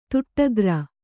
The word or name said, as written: ದಿಟ್ಟಗಾರ